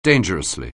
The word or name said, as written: dangerously